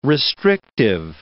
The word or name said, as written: restrictive